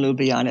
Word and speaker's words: Ljubljana: I have a very good friend in Ljubljana, as a matter of fact.